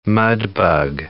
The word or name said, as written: mudbug